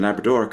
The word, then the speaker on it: Labrador: And Labrador comes from a Spanish word for farmer or laborer, and the accent is again on the third syllable of both.